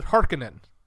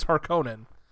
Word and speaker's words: Harkonnen: So in this movie, they pronounce it Harkonnen. – Yeah. – Harkonnen. – Yep. But the thing is that we actually have, we actually have Frank Herbert on audio talking about how things are pronounced. And from Frank Herbert himself, it's Harkonnen.